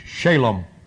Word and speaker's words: Shalom: The emphasis is S-H-A, long A, the emphasis on the first syllable, L-O-M, Shalom.